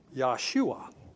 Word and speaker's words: Yahshua: But then, S-H-U-W-A-Y. So you would pronounce that Yahshua.